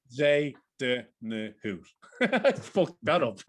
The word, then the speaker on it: Bezuidenhout: And my last pick is Christian Bezuidenhout.